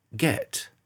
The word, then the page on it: get: British English